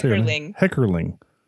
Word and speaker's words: Heckerling: So this was directed by Amy Heckerling. I gotta say Heckerling. I'm adding extra syllables.